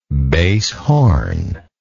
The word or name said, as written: bass horn